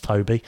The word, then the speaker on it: Tobe: For me, I've seen other Tobe Hooper films, or is it, it's spelt T-O-B-E, but I think it's pronounced Toby.